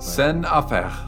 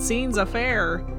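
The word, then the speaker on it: scènes à faire: In my copyright class, we just butchered it and said scene's affair.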